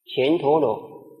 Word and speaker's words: 乾陀罗: 这个前字齁，也念作干，干字齁有两个读音，一个读音是乾，如同乾坤，乾隆皇帝；第二个读音是干，如同干净、干燥。因为这是国名，所以读作乾，乾陀罗。